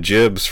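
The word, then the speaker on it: gibs: I always said Gibbs for Doom and Quake, and then I believe I heard someone actually pronounce it Jibs, short for giblets, and it just blew my mind. I've always said Gibbs. Has anyone else here said Gibbs who has used that term? – Nah. – Nope. – Ah, dang. – I'm very cool. – Ah. Well, I guess I'm not. I guess I default to the hard G.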